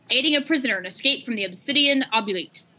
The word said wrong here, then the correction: Oubliette: I, I, I hope I pronounced that correctly. Oubliette. Yeah. Oubliette. – the Obsidian Oubliette.